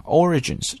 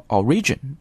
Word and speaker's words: origin: 好，再来往下看 Sumo origins lie in Shinto rites performed in temples 啊，介绍了一下这个相扑运动的起源啊。 origins 这个单词表示的意思叫起源，然后一定要注意啊它的发音，很多同学把它读成 origin, 不对，这个单词的重音在第一个音节，读的应该是 origin。